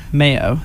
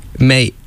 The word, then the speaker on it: Maye: And this is for Mayo, the artist. – Yeah. May? I think it's just May. – What's the E doing? – It certainly is not an O sound. – Spencer pronounced it Mayo. – Well, this is two by M-A-Y-E.